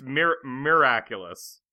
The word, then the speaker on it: miraculous: No, it's miraculous. – Miraculous. – Kevin can't say it either. It's miracle, it's miraculous.